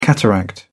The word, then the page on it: cataract: British English